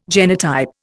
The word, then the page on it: genotype: Indian